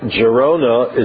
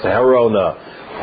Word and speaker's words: Girona: The town of Girona is actually pronounced Girona in Catalonia, in Spain, as you well know. The Spaniards, classical Spanish, the G is silent, so it's really pronounced Gerona. Gerona in the rest of Spain.